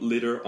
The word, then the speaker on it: litter: 근데 이 litter는 이 발음이 하고 조금 달라요. 이거는 짧은 이에요. 이는 아니고요. 이. 그래서 leader 아니면 liter이라고 하면은 지도자 아니면 리터. 이렇게 1리터, 2리터. 근데 여기 나온 거 litter.